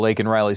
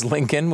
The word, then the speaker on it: Laken: Laken Riley, say her name. And he says it. He obviously says Lincoln, which is bad.